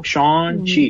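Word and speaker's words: Shang-Chi: Depende del éxito de Sean, de Hasta hoy me, desayuno que se pronuncia Sean, como Sean, como un nombre gringo, Sean Chi.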